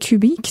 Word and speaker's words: Kubik: L'auteur est René Bouchara, l'éditeur est Cubic, ça s'écrit comme ça se prononce, K-U-B-I-K, et vous